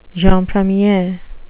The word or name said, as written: jeune premier